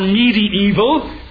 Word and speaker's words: medieval: Not an impersonal force, not a medieval concept or medieval, which is the proper pronunciation. I checked it earlier this morning. Though oft times we say medieval, it is three long E's, medieval.